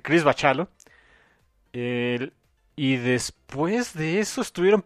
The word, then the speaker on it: Bachalo: Bendis y Chris Bacalo. Que ya me enteré que es Bachalo. – Bachalo. – Dice el buen Beto Calvo que el nombre, como se pronuncia, es Chris Bachalo.